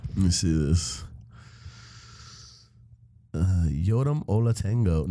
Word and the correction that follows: Ottolenghi: Ottolenghi. – Ottolenghi or something like that.